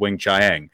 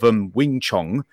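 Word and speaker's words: Weng-Chiang: Is a new my favorite one was though they kept mispronouncing Talents of Wing Chiang. They kept going now on BBC One, Talents of Wing Chong next. It's just quite funny hearing this very upper RP sort of BBC announcer completely mispronounce it.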